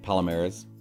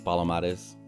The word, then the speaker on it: Palomares: Palomares. – How do you say it in Mexican? – Palomares. – There you go. Palomares. – Palomares, yeah. – It's like Matamoros. It's Matamoros. – Yeah, just like that.